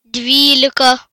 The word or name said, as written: 12